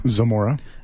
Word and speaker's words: Zamora: Zamora.